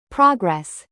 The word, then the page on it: progress: American English; noun